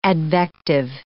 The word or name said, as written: advective